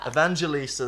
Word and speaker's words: Evangelista: He was Evangelista. – Evangelista, there we go.